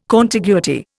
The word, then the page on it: contiguity: Indian